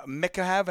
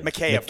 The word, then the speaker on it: Mikheyev: You mentioned, and we've been talking about free agent coming out of Toronto, obviously Ilya Mikheyev, and I'm saying his name wrong. – Mikheyev.